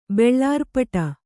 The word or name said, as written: ಬೆಳ್ಳಾರ್ಪಟ